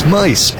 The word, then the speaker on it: Meis: And it is Mice, M-E-I-S. Pronounced like the 3 blind mice.